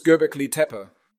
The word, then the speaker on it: Göbekli Tepe: Ich musste Sie fragen, denn ich habe gerade von dieser archäologischen Stätte in der Türkei erfahren und das Göbekli Tepe. Kann es einfach nicht aussprechen. – Es ist Göbekli Tepe.